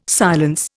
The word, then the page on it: silence: Indian